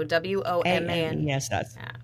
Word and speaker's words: Womaness: So W O M A N E S S.